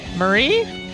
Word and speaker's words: Maire: Her name is Mare Holo. – Marie? – No, it's spelled M-A-I-R-E. – Oh. – H-O-L-L-L-O.